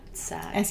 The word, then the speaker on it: SAG: He started off running for union positions within SAG. Is that how you say it? Or do you do Screen Actors Guild? – SAG. – SAG, whatever. SAG.